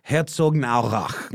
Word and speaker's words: Herzogenaurach: Herzogenaurach. – Herzogenaurach. – Herzogenaurach. – Got close. – It's close. I think I overpronounced the gen, the gen. So they're more like Herzogenaurach.